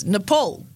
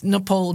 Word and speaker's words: Nepal: So apparently, this is Nepal now. – Nepal. – Nepal, right? He says it like that. – It's It rhymes with PayPal.